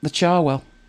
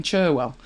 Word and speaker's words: Cherwell: The classic for me is the Charwell, which is spelt Cherwell. And the unknowing fall into the trap of saying Cherwell.